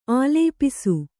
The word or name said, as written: ಆಲಾಪಿಸು